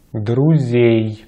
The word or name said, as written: друзей